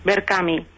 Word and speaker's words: Verkami: Berkami. – ¿Sí? – Que se escribe con K, con sí. K de kilo.